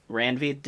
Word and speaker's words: Randvi: I'm wondering if you got stuck kind of like how I got stuck, because did you forget to go to, is it Radvi? Or how do you say her name? Randvi.